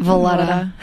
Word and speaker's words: Valletta: Wait, Rob Vallarta. How do you say his name? – Valletta. – Oh, I made him like, I don't know what I made.